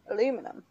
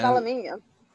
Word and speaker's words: aluminium: Say aluminum. – Aluminum. Yeah. That's aluminium. – That's wrong. So wrong.